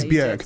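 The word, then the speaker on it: Björk: Well, it is Bjork. That is the correct pronunciation.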